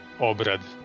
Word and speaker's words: Obrad: Obrad.